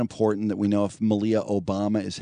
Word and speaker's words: Malia: Malia.